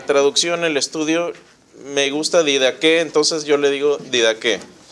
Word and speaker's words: Didaqué: Bueno, algunos estudiosos me preguntaba, perdón, Nicolás me preguntaba, ¿cuál es el nombre correcto? ¿Cómo se pronuncia? Bueno, se pronuncia Didaqué. Algunos le llaman, ¿sí? Didache. A mí no me gusta ese nombre. No me gusta, se puede traducir también así, pero normalmente el acento se la pone en la segunda E.